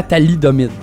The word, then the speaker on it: thalidomide: La thalidomide.